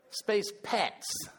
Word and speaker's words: Spacepets: Spacepots. I keep saying it, don't I? Spacepets. Spacepets. It's not an acronym if you actually say it right.